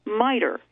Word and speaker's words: MITRE: MITRE. That's spelled capital M-I-T-R-E.